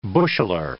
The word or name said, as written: busheler